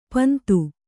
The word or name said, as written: ಪಿಂದು